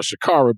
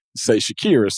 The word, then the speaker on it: Shakira: It's spelled, I thought I saw Shakara, but I heard him during the engagement or proposal, I should say Shakira.